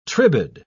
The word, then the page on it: tribade: British English